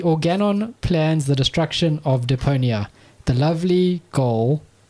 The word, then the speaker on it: Deponia: Goodbye, Deponia. Am I saying that right? – I think you're right. I would stick with that. – Deponia. – Just say it with confidence. It's fine. – Oh, yeah. Goodbye, Deponia.